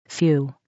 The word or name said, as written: few